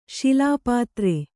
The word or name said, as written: ಶೈಲಪುತ್ರಿ